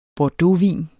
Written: bordeauxvin

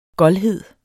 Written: goldhed

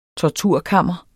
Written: torturkammer

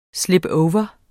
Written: slipover

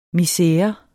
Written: MISERERNES

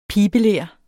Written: pibeler

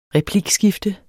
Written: replikskifte

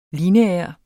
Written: LINEAR

